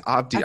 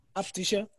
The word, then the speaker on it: Avdija: I've gone for, again, pronunciation apologies, Denny Avdija. – Is it Avdija? Avdija? I think it's Avdija. I think you're right. – Avdija. We'll say that anyway.